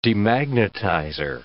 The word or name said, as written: demagnetizer